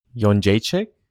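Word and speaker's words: Jędrzejczyk: Yon Jaychik? – There you go.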